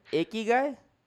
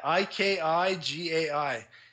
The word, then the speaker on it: ikigai: How do you say it? – guy? Ikigai. I yeah. I K I G A I.